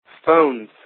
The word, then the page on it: phones: American English